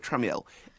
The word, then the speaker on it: Tramiel: Who is Jack Tramiel, is it? – I believe it's pronounced here, Tramiel.